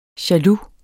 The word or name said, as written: jaloux